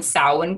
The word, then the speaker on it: Samhain: And I also want to applaud you in actually pronouncing Samhain correctly. I've heard that. – You mean instead of the traditional Samhain? – Samhain.